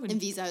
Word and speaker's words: Inviso: How do you say Inviso? Inviso? – Inviso is right, Inviso.